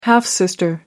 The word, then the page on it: half-sister: American English